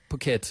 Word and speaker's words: Phuket: It rhymes with bucket, Phuket.